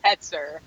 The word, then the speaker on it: Hoetzer: All right, yo, we got how do you say your last name? Is it Holzer? – My last one is super duper German, and it's actually super funny because I'm like not really German looking at all, but it's pronounced Hetzer.